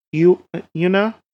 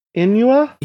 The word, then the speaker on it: Inua: The shape-shifting Yuna, demigod. No, wait, I said that wrong. Inua? – Inua? Yeah, Inua? I think it's Inua.